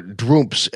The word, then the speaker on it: Trump: That is not least to avoid confusion with the other Trumps, or Droomps, as the name is pronounced in the Palatinate dialect, which is, I guess, the German thing.